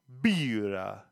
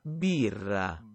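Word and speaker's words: Birra: Bira. Bira. Bira. That's how you pronounce it in Italian.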